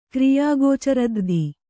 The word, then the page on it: ಕ್ರಿಯಾಗೋಚರದ್ರ್ಧಿ: kannada